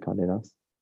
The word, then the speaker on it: Condé Nast: Is that how you say it? Condé Nast? – Yeah.